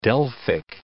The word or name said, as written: Delphic